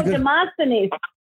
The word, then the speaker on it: Demosthenes: Demosthenes.